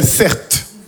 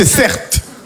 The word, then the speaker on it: Certe: Je m'appelle Certe. Mon prénom, c'est Certe. Ça s'écrit C-E-R-T-E.